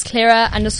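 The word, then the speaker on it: Claira: It's Clara _ Taverner, so it's C-L-A-I-R-A _T-A-V-E-N-E-R.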